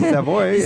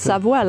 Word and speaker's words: Savoie: It sounds believable to me if his last name is Savoy. – Savoie. It's pronounced Savoie, like the region.